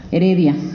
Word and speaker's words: Heredia: bueno, también tenemos La Jaula de Dios, de Rafael Ramírez Hereida, Heredia, lo dije mal, y La Vida Mental, de Henry Wallum.